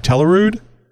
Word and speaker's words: Telluride: Telluride.